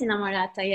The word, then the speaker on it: Inamorata: So for example, Inamorata, is that how you pronounce that? – Yes, Inamorata, yes.